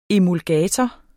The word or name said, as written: emulgator